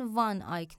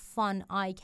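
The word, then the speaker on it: van Eyck: ببینید تقریبا در تمام کتاب های فارسی اسم این آدم رو یان وان آیک نوشتن اما تلفظ صحیحش فان آیک هست چون در زبان هلندی اون وی ف تلفظ میشه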